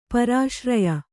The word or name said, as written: ಪರಿಸರ್ಯೆ